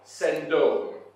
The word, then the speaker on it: sindon: So the linen garment that the guy's wearing in the Greek is a word that's spelled S-I-N-D-O-N, and it's pronounced something like sendon, something like that.